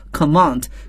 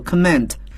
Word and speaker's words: command: 大家听我的发音呢，你会发现元音有明显的不同，在英国英语中呢很标准，我们要读作 command, 可是在美国英语中呢我们要读作 command, 统帅或者是指挥。